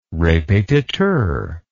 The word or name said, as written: repetiteur